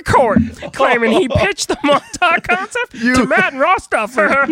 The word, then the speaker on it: Montauk: Charlie Kessler, I love the music, who directed the short film Montauk? Is that how you pronounce that? – Montauk, that's what I go with.